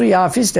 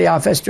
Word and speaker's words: Yafis: Hatta Yafes oğlu Yafis, esas Arapçada telaffuzu Yafis de, Türkçede Yafes diyorlar.